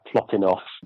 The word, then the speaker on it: Plotinus: I'd always thought of you as Plotinus. – Yeah, that's probably a more accurate pronunciation. I think in the Greek, it would be something like Plotinos, but in English, the usual pronunciation is Plotinus.